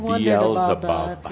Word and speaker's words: Beelzebub: There was a mispronunciation that perhaps I'm more concerned about than Caldra, but Beelzebub. – He was having a hard time channeling and pronouncing Beelzebub. So when your transcribers put all this, we don't want to terribly confuse him. Beelzebub.